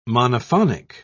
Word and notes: monophonic: British English

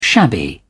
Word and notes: shabby: British English

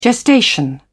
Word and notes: gestation: British English

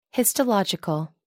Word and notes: histological: American English

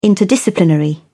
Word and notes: interdisciplinary: British English

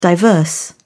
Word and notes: diverse: British English

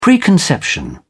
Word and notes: preconception: British English

